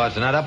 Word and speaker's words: Aznara: Se deletreo aznara por si alguno no lo puede escribir bien o yo lo pronuncio bien, pero Arancha al revés, AXTNARA, Aznara Grupo Tipsa.